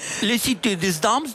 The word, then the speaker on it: Cité des Dames: Es que lo has pronunciado mal. La Cité des Dames, de Christine de Pizan. – La Cité des Dames, de Christine de Pizan.